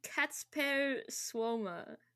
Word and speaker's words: Kacper Słoma: I might butcher it because it's Polish, but it's like Kacper Słoma.